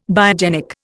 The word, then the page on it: biogenic: Indian